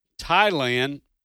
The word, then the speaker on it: Thailand: What was the nation of Thailand with that silent H?